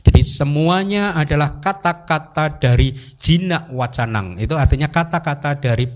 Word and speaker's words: jina: Buddha itu mempunyai julukan jina, J I N A. Bukan bukan bahasa Indonesia loh ya. J I N A, tapi memang bacanya jina gitu.